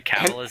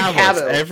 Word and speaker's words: Cavill: You're putting too much emphasis on the wrong syllable. Go ahead. We know who you're talking about. – Cavill. Henry Cavill.